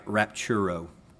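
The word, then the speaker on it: raptura: When this Greek word was translated into Latin, they use the Latin word that's pronounced like rapturo.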